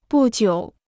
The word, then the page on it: 不久: bù jiǔ